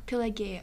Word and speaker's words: Pelagia: Pelagia. – Was I close? Pelagia. Say it again. – Pelagia. – Pelagia. – That's good. It's you can say like that. – It's kind of a soft G.